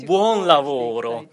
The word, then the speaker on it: buon lavoro: Io ho saputo che quando in Italia ci si accommiata da chi è impegnato nel proprio lavoro, lo si saluta dicendo buon lavoro, spero che la pronuncia sia giusta. Io ammiro, grazie. – Buon lavoro. – Buon lavoro, ecco.